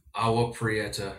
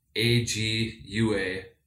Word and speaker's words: Agua Prieta: And so we crossed from Douglas, Arizona into Agua Prieta. It's pronounced that. I think I got that pretty close. But the spelling would be AGUAPRIETA. Is that right? – I think that's correct, yes.